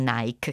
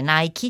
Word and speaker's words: Nike: così come Nike si legge con la E finale e non Nike, che noi siamo spesso tendiamo spesso a dire Nike, invece Nike, gli americani dicono Nike, anche se poi in realtà sarebbe Nike, la dea della vittoria greca, piccolo spunto così di cultura classica.